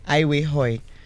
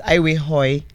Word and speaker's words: Aiwekhoe: And Aiwehoi is my surname, my husband, my married name. – Aiwehoi. – Yes. A-I-W-E-K-H-O-E. So the K is silent.